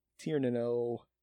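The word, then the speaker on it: Tír na nÓg: Tir-na-no. – Is it? – It sounds like Sir-na-no, but it's different.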